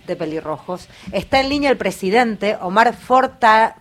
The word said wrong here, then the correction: Fornataro: Fornataro, ahí lo dije bien Omar, perdón, eh, para charlar un poco con nosotros.